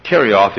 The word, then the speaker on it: Kerioth: We'd spell it K-E-R-I-O-T-H, Kerioth.